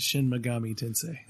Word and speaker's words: Shin Megami Tensei: Shin Mega Tensei. Uh, I think I said that right. Shin Megami Tensei. I almost got there.